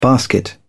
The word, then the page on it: basket: British English